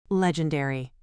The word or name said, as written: legendary